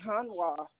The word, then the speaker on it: Hanwha: As far as I know, it's Hanwha. – Hanwha. And spell that for me one more time. Spell it for me one more time. I want to double check. – H-A-N-W-H-A. Hanwha. – Hanwha.